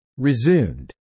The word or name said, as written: resumed